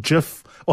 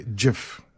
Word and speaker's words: GIF: I'm so glad you said it properly, GIF. – I'm glad I did. I've never said it before. – Because a lot of people say GIF, but it's actually GIF.